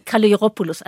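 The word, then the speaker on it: Kalogeropoulos: Also man sagt äh, Kaleropoulos. Also spricht man eigentlich wie ein J aus. Also man spricht das G nicht als G aus.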